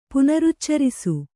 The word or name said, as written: ಪುನರುಚ್ಚರಿಸು